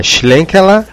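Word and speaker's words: Schlenkerla: Agora o chileno vai me, vai me ajudar aqui na pronúncia, mas eu vou falar minha pronúncia sorocabana primeiro, aí o chileno fala a pronúncia alemã. É a Altschlankerla Hausbier. Como é que chama essa porra, hein? Sei lá, mano, se vira. – Agora a pronúncia como bonita. Bonita. É Schlenkela Rauchbier.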